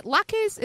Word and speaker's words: latkes: Oh, let me ask you this, because Sam says that, is it latkes? Am I pronouncing it right? – Latkes, yeah. – Latkes, okay.